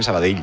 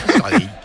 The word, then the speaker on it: Sabadell: El cachondo de Kiki Lesa dice, pronuncias muy bien Sabadell. Y dice, mis respetos. – Sabadell. A mí me lo decían mucho cuando estaba en Primera Federación, porque yo también decía Sabadell, que es como se dice.